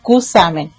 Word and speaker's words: Cusamen: nosotros somos de Cusamen. Así como se escribe, así lo nombramos, y nos gustan que nos nombren así y no con el arrastre, digamos, de entre la S y la H, así como sonando como Y, no.